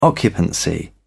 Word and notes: occupancy: British English

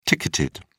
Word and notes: ticketed: British English

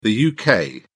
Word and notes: UK: British English

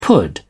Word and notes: pud: British English

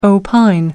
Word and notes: opine: American English

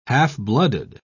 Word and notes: half-blooded: British English

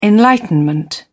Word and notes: enlightenment: British English

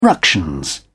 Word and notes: ructions: British English

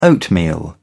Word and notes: oatmeal: British English

